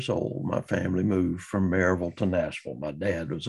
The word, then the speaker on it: Maryville: And in Nashville, I was from Maryville, Tennessee, which properly pronounced in Maryville is Merville. And you move to Nashville and people in Nashville say M-A-R-Y as Mary.